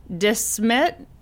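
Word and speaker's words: De Smet: And she marries 28-year-old Almanzo Wilder in Desmet, South Dakota. – How do you spell that? – D-E, capital S-M-E-T.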